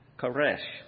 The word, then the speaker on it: charis: Grace is the most frequently used in modern Bible translation for the original Greek word, charis. And that's spelled C-H-A-R-I-S.